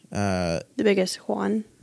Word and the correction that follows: yuan: Sorry. I probably mispronounced it too. – Yuan. – Yuan. Oh. – Not Juan, idiot.